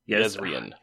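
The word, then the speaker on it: Jezrien: Yezaria. Is it Yezaria? – Yezrian. – You know I'm not going to pronounce names right.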